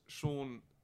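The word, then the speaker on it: Seann: My name is Sean Walsh with two N's. – Does you pronounce it any differently? – Sean and Walsh. – It's Sean Walsh, yeah.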